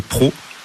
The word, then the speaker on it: Préau: Et ensuite, on va sortir des bois et on va passer au hameau des Pros. Ça s'écrit Préau, mais on dit Pro dans le Pila.